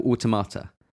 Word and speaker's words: Automata: How do you say it, by the way? Automata or automata? – Automata. – Automata. Cool. That's what I know. – I don't know. That's right. – I feel like I hear maybe it's Americans say automata or something like that. Automata. We're going to go automata.